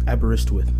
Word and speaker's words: Aberystwyth: Aberystwyth.